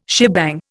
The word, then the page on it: shebang: Indian